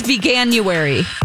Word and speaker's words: Veganuary: I think it's Veganuary. – Okay, that's too difficult. – Because that would be the right emphasis in the syllable.